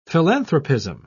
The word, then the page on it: philanthropism: British English